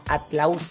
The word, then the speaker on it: Atlautla: El padre Huitrón es párroco de San Miguel Arcángel en el municipio de Atlahuatla, Atlautla, Atlautla. – Atlautla. – Atlautla, estoy muy mal con mi pronunciación.